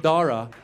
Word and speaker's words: Dara: It's the most entertaining shit because his teachers don't know what to do with the silent H in Dara. And they don't want to mess it up. They don't want to offend us. So we walk in. I see his white teachers. They're sweating bullets. And they start saying his name, hoping that we'll help them out. They're like, you know, your son, Daha, Daja, I mean, there's no kha. Where'd you see the kha? The H is silent.